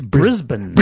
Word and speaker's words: Brisbane: The album is called We're Not Talking, and they're from, you're not supposed to say Brisbane. You go, Brisbane. – It's Brisbane. – Brisbane. Yeah. Yeah, so you got to make sure you pronounce that correctly.